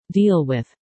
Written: deal with

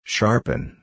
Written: sharpen

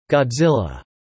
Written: Godzilla

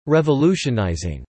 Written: revolutionizing

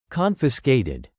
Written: confiscated

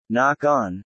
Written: knock-on

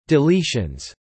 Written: deletions

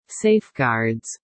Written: safeguards

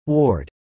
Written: ward

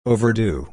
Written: overdue

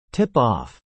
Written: tip-off

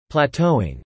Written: plateauing

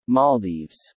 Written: Maldives

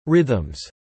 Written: rhythms

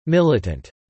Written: militant

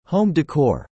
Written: home decor

